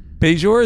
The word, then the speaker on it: Bajor: The question is Bajor the right? Is it Bajor? Is that how you say it? – It's both. Who cares? – It's both.